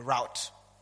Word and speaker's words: route: Well, people call it the route. I've been stuck with pronouncing it as root.